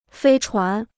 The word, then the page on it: 飞船: fēi chuán